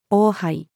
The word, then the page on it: 王配: female